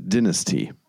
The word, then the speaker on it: Dynasty: Es heißt Dynasty. – Ja. – So, Blood Dynasty. – Ja. – Um das mal richtig gesagt zu haben.